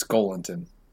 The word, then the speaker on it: Joelinton: Also, we've been saying Joelinton's name wrong, but you know what? We're just going to keep rolling with it. His name is definitely Joelinton. – No, it's Golinton.